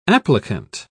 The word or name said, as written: applicant